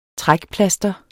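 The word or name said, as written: TRÆKPLASTERETS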